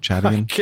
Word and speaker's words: Chadian: Is that the way you'd pronounce that? From Chad? Chadian? – Yes.